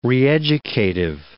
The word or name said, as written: reeducative